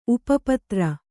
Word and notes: ಉಪಪತ್ರ: upa patra

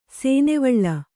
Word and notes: ಸೇನೆವಳ್ಳ: Kannada

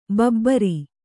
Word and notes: ಬಬ್ಬರಿ: babbari

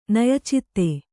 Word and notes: ನಯಚಿತ್ತ: naya citta

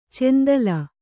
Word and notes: ಚೆಂದಲ್: cendal

